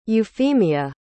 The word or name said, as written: euphemia